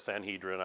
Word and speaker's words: Sanhedrin: In the ancient land here of Israel, there's a court system called the Sanhedrin. Some people pronounce that Sanhedrin. I won't pick on you if you do.